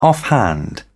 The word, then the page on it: offhand: British English